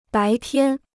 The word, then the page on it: 白天: bái tiān